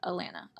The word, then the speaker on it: Alana: Her name is pronounced like Atlanta without the T. Everyone pronounced it wrong throughout the whole season, but Alana.